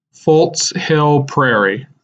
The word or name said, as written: Fults Hill Prairie